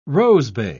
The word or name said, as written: rosebay